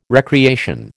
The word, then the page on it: recreation: American English